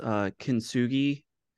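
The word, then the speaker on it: kintsugi (金継ぎ): It's like I believe it's pronounced kintsugi.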